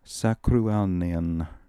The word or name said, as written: Sakrooalnian